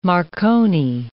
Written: Marconi rig

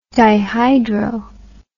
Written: dihydro

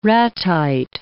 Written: ratite

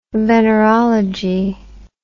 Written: venerology

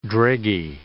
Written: dreggy

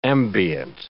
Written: ambient

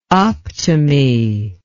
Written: optime

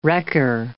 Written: wrecker

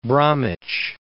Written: West Bromwich

